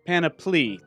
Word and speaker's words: Panoply: Panoply. – Panoply. – Panoply? – Panoply. That's P-L-E-E there at the end.